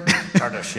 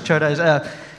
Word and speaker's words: Csárdás: So when it came to Csárdás, am I saying this right? – Csárdás, yes. – Csárdás.